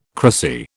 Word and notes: cracy: British English

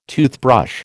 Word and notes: toothbrush: American English

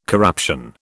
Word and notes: corruption: British English

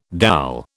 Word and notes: dal: British English

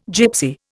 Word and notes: gipsy: Indian

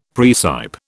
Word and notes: praecipe: British English

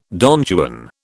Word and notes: Don Juan: British English

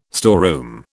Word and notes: storeroom: British English

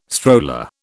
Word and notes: stroller: British English

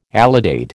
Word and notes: alidade: American English

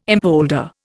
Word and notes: impolder: Indian

